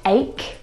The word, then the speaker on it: ache: Words like stomach and ache. The CH in all of these words is pronounced like a K sound.